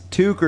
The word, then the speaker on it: toque: Now what he's referring to is Bob and Doug McKenzie and the pronunciation of the word that means Canadian hat. – Toque or toque, as we said.